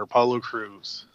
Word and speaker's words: Apollo Crews: Apollo Crews.